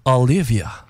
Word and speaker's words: Olivia: Donc, si vous vous dites, ça fait pas tellement francophone, je vais aller prononcer à l'anglaise. Olivia.